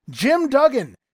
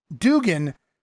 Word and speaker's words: Duggan: And then up next, newcomer to the Georgia territory, the Big Moose, Jim Duggan, in the ring scoring a win over Jim Jones. As Gordon Sully mispronouncing Duggan's name all throughout the matchup, referring to him as Dugan, leading to this little ditty from the future Hacksaw.